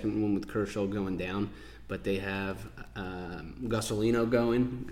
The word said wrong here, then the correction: Gonsolin: I don't know how to say his name. – Gonsolin.